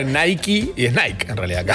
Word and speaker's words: Nike: It's Nike, I'm sorry. Está Actually, not sorry, that's just how you say it.